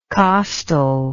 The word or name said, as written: costal